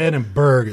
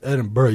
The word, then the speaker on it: Edinburgh: It's not Edinburgh. It's Edinburgh. You swallow that G, man.